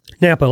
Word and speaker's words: Knappa: Knappa, like Napa, Idaho, but Knappa.